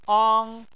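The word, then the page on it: ang: tone 1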